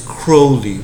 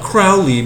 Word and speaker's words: Crowley: Like like a lot of biographers, like on Aleister Crowley, they would be like, and you got to make sure you say his name right, and it's Crowley, like like the bird crow, not Crowley because Ozzy Osbourne.